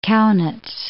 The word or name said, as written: Kaunitz